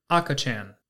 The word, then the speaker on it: akachan: Today's Lessons is going to be the Japanese word for baby, and that is akachan. That is spelled A-K-A-C-H-A-N.